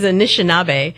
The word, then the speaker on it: Anishinaabe: The word is Anishinaabe.